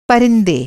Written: परिंदा

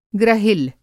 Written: ग्रहिल

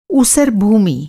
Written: ऊसर

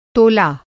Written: तोला

